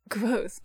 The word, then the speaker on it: Kvothe: I thought his name was Kvothe until I read it, and there's the part of the book where he's like, my name is Kvothe, pronounced almost, but not quite like the word Kvothe.